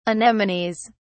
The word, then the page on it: anemones: British English